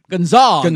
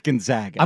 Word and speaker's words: Gonzaga: Gonzaga. – No, it's not. It's Gonzaga. – I'm like, how do you say R-A-G? Rag? Okay. So why would it be Gonzaga and not Gonzaga? I don't get it.